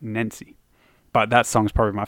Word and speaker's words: Nenci: I, I think he pronounces it, it Nancy, but it's spelt N E N C I.